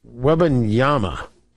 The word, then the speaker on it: Wembanyama: I think I just pronounced his name wrong right there. I always do that. It's Wembanyama. It's with an A, right? I mean, it's with an M. Wembanyama. I said Wembanyama. Wembanyama. There it is.